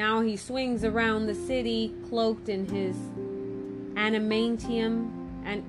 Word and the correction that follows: anonymity: Check if I said it right. – Oh, anonymity. – Oh, sorry, my bad. – Anonymity, guys.